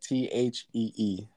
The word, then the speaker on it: Thee: Yeah, like THEE, The Sacred Souls.